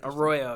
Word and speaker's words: Arroyo: And Coach Marcus Arroyo, did I say it right? – Arroyo, you got it. – Arroyo, yeah.